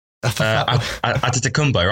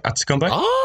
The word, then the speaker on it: Antetokounmpo: Yeah, but his name, funnily enough, is spelt exactly how it's said. Her name is spelt G-H-I-S. – His name is onomatopoeia. What do you mean? – Antetokounmpo.